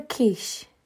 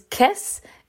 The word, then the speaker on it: Kiss: Now, um, I did talk about this briefly on TikTok, and I kept and I pronounced his name as Kiss instead of Quiche because I'm an idiot who sometimes does not pronounce things correctly, and I was I was quickly corrected twice. good, good for you. Um, I was quickly corrected twice regarding my mispronunciation of of quiche but it looks like KISS in English.